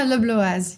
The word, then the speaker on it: Le Bloas: Yeah, Le Bloas, yeah.